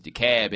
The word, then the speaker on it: DeKalb: On I don't know how y'all pronounce it here. It's DeKalb in Atlanta.